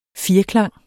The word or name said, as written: firklang